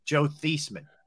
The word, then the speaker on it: Theismann: He said, we're going to change the pronunciation of your name to Theismann to rhyme with Heisman so you can try and win the Heisman trophy.